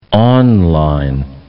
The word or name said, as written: online